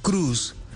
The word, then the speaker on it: Cruise: elimine el registro o anule el registro del nombre Cruz y Super Cruz, se escribe Cruise.